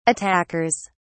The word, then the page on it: attackers: British English